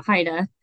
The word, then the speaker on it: HIDTA: Well, that, so the acronym, we pronounce it HIDA.